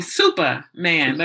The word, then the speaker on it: Supaman: So you wrote the book Raising Superman. I mean Supaman. Let me say it correctly.